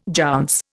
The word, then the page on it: jounce: Indian